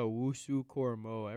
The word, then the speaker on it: Owusu-Koramoah: How do you pronounce his name? – Jeremiah Owusu-Koramoah. Everyone just calls him JOK.